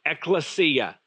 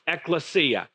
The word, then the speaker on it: ekklesia: And this word that he chose to use in Greek is pronounced ekklesia, ekklesia, which means a gathering, an assembly, a congregation of people is what it really means.